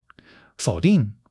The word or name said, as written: fou3 ding4